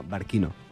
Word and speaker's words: Barcino: En llatí la pronunciació de la C és com la K. Barquino.